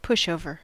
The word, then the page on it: pushover: American English